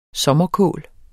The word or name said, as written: sommerkål